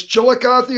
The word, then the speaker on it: Chillicothe: Yeah, it's Chillicothe, Ohio. It is kind of hard. – Chillicothe.